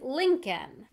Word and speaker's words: Lincoln: The L toward the end of his last name is not pronounced. President Lincoln.